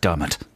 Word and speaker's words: Diarmaid: Which, now, this person was trying to trick me out with their name because it's spelt D-I-A-R-M-A-I-D. Diarmaid. – Diarmaid. – But I looked it up on the internet. – Nice. – And I know it's pronounced Dermot.